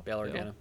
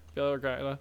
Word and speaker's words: Bail Organa: Am I saying his name right? – Bail Organa. – Yeah. – Bail? Okay. Bail Organa.